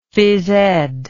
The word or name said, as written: physed